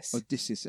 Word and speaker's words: Odysseus: Is it Odysseus? – Odysseus.